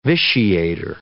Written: vitiator

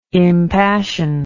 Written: impassioned

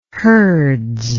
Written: hurds

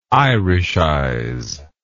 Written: irishize

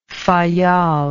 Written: Fayal

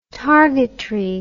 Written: targetry

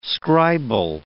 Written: scribal

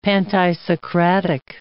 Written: pantisocratic

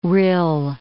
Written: rill

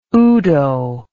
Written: udo

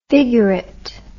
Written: figurately

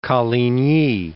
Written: Coligny

